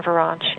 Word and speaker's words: Vuranch: But also Facebook, if you like me on Facebook, and just do my name, you can either do WV Enterprises or Karen Verange, and the Verange is spelled V-U-R-A-N-C-H. People like to put extra A's and U's in it, but it's very simple. B-U Ranch.